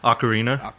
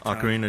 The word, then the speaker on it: Ocarina: Ocarina. Ocarina of Time.